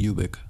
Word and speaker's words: Ubik: It comes from the word ubiquitous, so it would be Ubik, I would think.